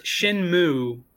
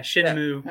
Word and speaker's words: Shenmue: But for the record, I looked it up. It's pronounced Shenmu. – Shenmu. – Don't whatever. Actually pronounce the E. Yeah, Shenmu.